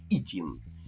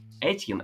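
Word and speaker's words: Etienne: Кстати, э, я слышал э, в некоторых телевизионных э, новостных трансляциях, что Трэвис, Трэвис Итьена производит его, произносит его фамилию как Итин. Не Этьен, а именно Итин.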